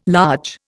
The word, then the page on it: large: Indian